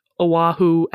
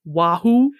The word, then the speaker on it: Oahu: He keeps pronouncing Oahu as Wahoo. – Wahoo. – Wahoo. And he keeps calling centipedes santipedes. S-A-N-T-I.